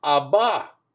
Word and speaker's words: Abba: But in Aramaic, the word for father is Abba, A-B-B-A.